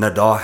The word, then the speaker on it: Nadal: I'm pretty sure I said it correctly, but I'm just going to say it just in case I said it wrong. – Mm-hmm. – Nadal has 19 seasons with at least one title. – Yeah, you did say right.